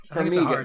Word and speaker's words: Farmiga: You gave me Vera Farmiga. Is that how you pronounce her name? – I don't know. – Farmiga. – Farmiga. That's, yes, yeah. You're definitely right. It's definitely not that thing I said.